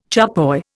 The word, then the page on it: charpoy: Indian